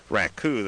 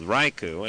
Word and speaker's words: Raku: But all I'm saying is that jackass called me from his junior college to correct me on my pronunciation of the firing of clay technique known as Raku that I called Raiku.